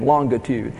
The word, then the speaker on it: longitude: I pronounce that with a Brit pronunciation, Longitude.